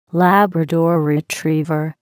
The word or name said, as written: Labrador retriever